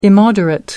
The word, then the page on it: immoderate: American English